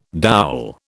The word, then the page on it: dowel: British English